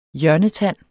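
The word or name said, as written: HJØRNETAND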